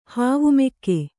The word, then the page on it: ಹಾವುಮೆಕ್ಕೆ: hāvu mekke